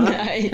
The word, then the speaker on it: gneiss: It's also an igneous rock. It's spelled G-N-E-I-S-S. – Uh-huh. Nice. – And it's pronounced nice.